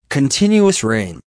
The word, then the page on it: continuous rain: American English